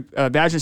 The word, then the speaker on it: Vajna: It's V-A-J-N-A, Vajna.